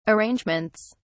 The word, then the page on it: arrangements: British English